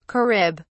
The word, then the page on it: carib: British English